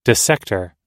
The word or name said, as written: dissector